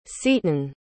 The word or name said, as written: seeton